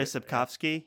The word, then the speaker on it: Sapkowski: Did I say that right, Charles? Sapkowski? – Yeah, Andrei Sapkowski.